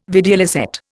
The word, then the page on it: videlicet: Indian